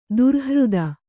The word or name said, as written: ದೌರ್ಹೃದ